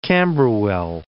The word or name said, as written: Camberwell